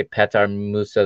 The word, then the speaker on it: Petar: Finally, in the 67th, Peter Musa replaces Gonçalo Ramos. Or should I say Petar Musa? That's the correct pronunciation.